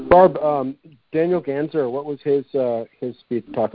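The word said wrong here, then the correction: Daniele: Daniela. I learned he pronounces it Daniela.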